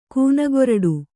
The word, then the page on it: ಕೂನಗೊರಡು: kūnagoraḍu